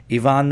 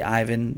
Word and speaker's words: Ivan: How do we pronounce his name? How do you pronounce his name? Ivan Nova? I've always gone with Ivan. I know a couple of people who like to say Ivan Nova. We're going to go with Ivan on Yankees and Gravy.